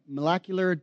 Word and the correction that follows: macular: degeneration? Is that right? Macular degeneration. Thank you.